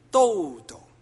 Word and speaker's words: Dodo: 啊，文说咧有一个知名人士，佢嘅英文名爱好或者佢嘅英文爱好 nickname 啊，系咁样串嘅 D.O.D.O。咁咧认识佢嘅人都称呼佢咧做 Dodo。不过根据英国牛津字典 Oxford Dictionary 或者是美国的韦氏字典 Webster's Dictionary，正确的发音是 Dodo。